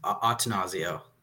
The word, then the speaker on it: Attanasio: By the way, was what's the exact pronunciation? – I believe it's just Attanasio.